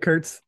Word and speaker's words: Kurtz: And I think Kurtz, is that his name? Am I saying that right? – Nick Kurtz, Wake Forest first Yeah.